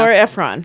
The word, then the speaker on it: Nora Ephron: Nora Ephron.